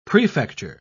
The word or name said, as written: prefecture